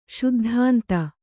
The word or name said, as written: ಶುದ್ಧಾಂತ